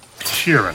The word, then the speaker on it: Sheeran: Frank Sheeran. Am I saying that right? – Yeah, you said it right.